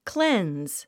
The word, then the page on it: cleanse: klénz; クレンズ